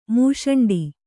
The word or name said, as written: ಮೂಸುಂಡಿ